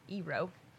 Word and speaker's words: Eero: It's E-E-R-O, so Eero.